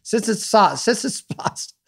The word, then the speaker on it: Tsitsipas: I had tied to Djokovic, so I had like three parlays remaining, and he was a minus 345 favorite over Tsitsipas. How do I say that? Why didn't I had it right? Tsitsipas.